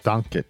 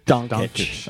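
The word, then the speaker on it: Dončić: He's on the Mavericks, number 77, Luka Doncic. Is that his name? – Or Doncic. – Doncic.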